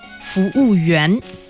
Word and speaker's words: 服务员: Okay, bathroom, and then what about the other, maybe 服务人? – 服务员. – Oh, 服务员.